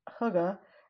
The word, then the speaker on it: hygge: It was, it's spelled H-Y-G-G-E. And I think it's like hygge.